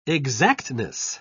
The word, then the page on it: exactness: British English